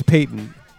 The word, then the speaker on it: Payton: I think it's Peyton. I was saying Patton Locke for a long time, but fuck it. So this is Patton Locke off the new Dust Bowl album. – It looks like it's spelled like Walter Payton.